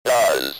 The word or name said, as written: does